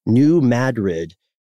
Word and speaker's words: New Madrid: And this is the New Madrid. We pronounced it correctly this time, Matt.